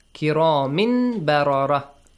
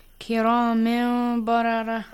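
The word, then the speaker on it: kiramin bararah: Tanwin min ba. Kiramin barara. How do you say this? Kiramin barara. – Kiramin barara. – Ahsan. Kiramin barara. But like stretch the sound. Okay, for two counts.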